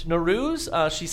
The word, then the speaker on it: Nowruz: It's Nowruz. Am I saying it right? Nowruz. She cites the source, the past 19 years of my life.